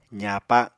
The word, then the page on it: zaapa: apatani